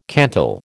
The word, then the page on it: cantal: American English